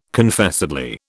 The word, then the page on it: Confessedly: British English